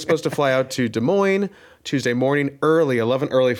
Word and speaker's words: Des Moines: Just clarification, Des Moines is spelled Des Moines.